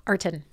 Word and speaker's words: Arten: Arten? How do you say your name? – Arten. – Arten. Okay.